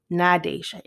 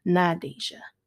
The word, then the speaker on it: Nadesha: And I'm like, no. Nadesha. It is Nadesha. It's only three syllables, just like Stephanie or Ashley.